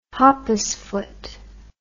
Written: hoppus foot

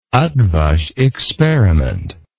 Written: eotvos experiment

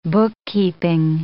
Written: bookkeeping

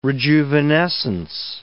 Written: rejuvenescence

